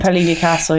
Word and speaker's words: Piolini Castle: But then you had the wonderful uh, Fabienne Piolini Castle, if I pronounce that yeah, correct. Piolini Castle, yeah.